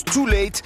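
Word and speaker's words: Tul8te: Son nom s'écrit de manière stylisée avec un U et un 8, mais ça se prononce Too Late.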